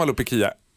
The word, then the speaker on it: alopecia: Det man ska veta om Alopecia eller alopsi eller hur det nu uttalas. Det är ju välkänt i Sverige som alopsi. Nu fick vi lära oss i pausen att ett grekiskt ord av Alexandra Pascalidou, alopeki. – Ja, men nu, det är ju, på svenska uttalas det alopecia.